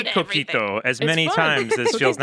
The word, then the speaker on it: coquito: Our wonderful audio engineer this morning was just telling me about coquito.